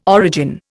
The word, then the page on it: origin: Indian